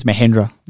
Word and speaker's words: Mahendra: I'm pretty sure I said that wrong, but anyway. – I think it's Mahendra.